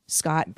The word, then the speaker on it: Schott: And by the way, the character Wynn Scott. – Thank you. – It's spelled S-C-H-O-T-T.